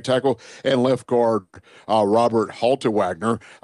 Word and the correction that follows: Haltewanger: I think I said that right. – Haltewanger, now that I see the N is before the G, excuse me.